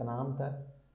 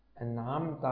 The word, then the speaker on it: أنعمت: حاول حاول يا أخي الحبيب أن تنطق النون والميم في كلمة أنعمت ساكنتين فقط بدون قلقلة. أنعمت عليهم، أنعمت عليهم.